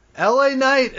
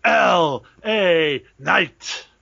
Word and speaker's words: LA Knight: LA Knight is in the building and he's walking to the ring. – I'm sorry, you said that wrong. It's LA Knight.